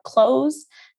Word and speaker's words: Cloze: The one I use is called Cloze. It's spelled with a Z, C L O Z E.